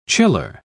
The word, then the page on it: chiller: British English